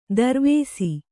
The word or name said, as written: ತಿರುವಿಸು